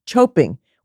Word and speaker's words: chope: It's called choping, which rhymes with hoping, as in, we're hoping someone unfamiliar with our traditions doesn't try to sweep the tissues off our table.